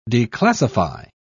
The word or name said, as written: declassify